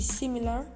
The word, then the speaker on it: similar: Similar es como se pronuncia en español y similar en inglés.